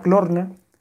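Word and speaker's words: Klarna: se llama Clorna, se escribe Clarna,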